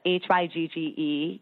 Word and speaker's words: hygge: Like it's it's like very I can't I can never pronounce this word H Y G G E